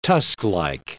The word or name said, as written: tusklike